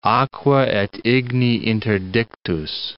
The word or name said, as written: aqua et igni interdictus